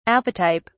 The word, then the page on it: apotype: British English